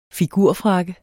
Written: figurfrakke

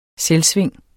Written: selvsving

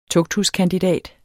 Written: TUGTHUSKANDIDATENS

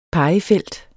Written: pegefelt

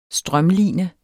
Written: strømline

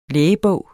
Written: lægebog

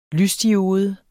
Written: lysdiode

